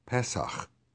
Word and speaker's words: Pesach: To commemorate this historic deliverance and to proclaim the future final deliverance, the Eternal established the Feast of Passover, in Hebrew, Pesach, stress on the E.